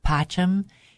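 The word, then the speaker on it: pacem: So if you hear, I didn't take Latin, I'm embarrassed to say, but if you hear someone who has taken Latin say that word, PACHM, it's a two-syllable word and it's beautiful.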